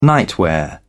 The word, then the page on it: nightwear: British English